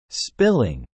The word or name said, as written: spilling